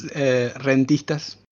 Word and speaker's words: Rentistas: Close enough. It's Rentistas.